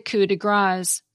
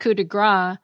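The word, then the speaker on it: coup de grâce: while fix is spelled F-I-X-E. It's not a pre-fee meal, as I've heard some servers call it. And the finishing touch on a job is the coup de grace, because grace is spelled G-R-A-C-E. It's not coup de grace, which literally means a strike of fat.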